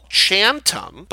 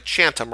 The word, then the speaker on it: Chantum: Chantum, but say it like one word, like Chantum rhymes with Phantom.